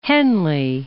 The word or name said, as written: Henley